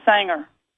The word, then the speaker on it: Sanger: Being in Houston, it's like, think of it as like a country western Sanger.